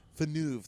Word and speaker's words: Villeneuve: Villeneuve, that's right.